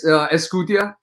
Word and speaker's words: Excutia: It's okay. It's Excutia. Excutia, you said it right.